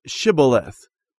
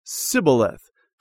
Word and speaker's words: Shibboleth: If he said no, then they would say to him, "Then say Shibboleth." And he would say Sibboleth, for he could not pronounce it right.